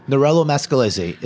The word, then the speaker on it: Nerello Mascalese: Nerello Mascalese is the grape. So it's right. hard to pronounce for some people. – M-A-S-C-E-L-L-E-S-E.